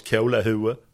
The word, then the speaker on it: kolahuvud: Du kallar någon för ett kolahuvud. Det uttalas kolahue.